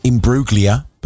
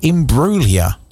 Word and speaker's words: Imbruglia: For years, I pronounced her surname Imbruglia, but I was corrected recently. Natalie Imbruglia.